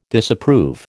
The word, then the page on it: Disapprove: American English